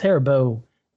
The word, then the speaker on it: Haribo: It's Haribo gummy bears on the rider.